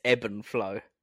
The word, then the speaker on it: ebb and flow: It's herb and flow. – It's ebb and flow. – Ebb and flow, sorry.